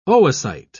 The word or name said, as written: oocyte